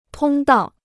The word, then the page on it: 通道: tōng dào